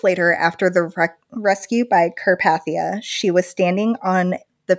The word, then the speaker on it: Carpathia: It's Carpathia. Like the Carpathian Mountains where, right. where Count Dracula lives.